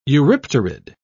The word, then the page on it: eurypterid: British English